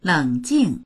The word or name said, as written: leng3jing4